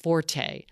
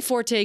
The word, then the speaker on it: forte: But the actual first pronunciation is fort. People say forte because it does sound kind of fancier, and forte right. – is meaning loud, actually, in other languages. But it is an accepted pronunciation.